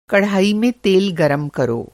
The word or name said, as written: कढ़ाई